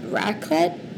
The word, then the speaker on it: raclette: I have to ask about raclette.